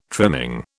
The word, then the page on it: trimming: British English